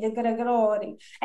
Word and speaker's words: DeGregori: Roger's telling me how to pronounce his name properly, so we're going in the Italian way, DeGregori.